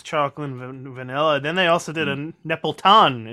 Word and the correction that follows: Neapolitan: Is that how you pronounce it? – Nepletan. – Nepletan. – That's how I just say Italian. It's just Neapolitan, if you wanted to say it.